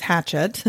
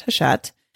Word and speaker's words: Hachette: You might have seen the name before and thought it was Hatchet, but I think it's a French company. It's pronounced Hachette. Actually, if it's French, it would probably be Hachette.